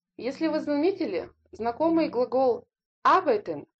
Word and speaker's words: arbeiten: wohnen – жить, denken – думать, heißen – называть, называться, lernen – учить, studieren – учиться, verdienen – зарабатывать, arbeiten – работать, kommen – приезжать, приходить, sprechen – говорить, speichern – сохранять, сберегать.